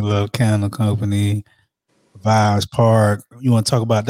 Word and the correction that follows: Vibe Park: So I said it wrong. My bad. Vibe Park.